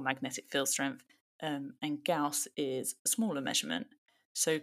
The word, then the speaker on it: Gauss: Gauss, yeah.